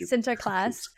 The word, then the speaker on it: Sinterklaas: Google how to say like the proper name, but I like saying Sinterklaas better. But let's yeah, say it right, Sinterklaas.